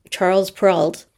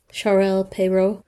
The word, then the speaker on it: Charles Perrault: In 1696, a Frenchman, Charles Perrault, saying it like you spell it, or saying it in French, Charles Perrault, possibly with the help of his son Pierre.